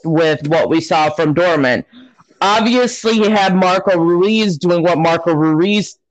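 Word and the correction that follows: Reus: – I think I pronounced that right, Royce. It's Marco Royce.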